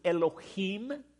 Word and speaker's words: Elohim: The word God in the Hebrew is Elohim. Anytime you come across a word in Hebrew that ends with im, I am, in the Hebrew, we would pronounce it E-E-M.